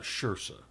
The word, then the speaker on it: Saoirse: Saoirse, not Saoirse. – Saoirse. Yeah. – Saoirse Ronan. – Rhymes with inertia.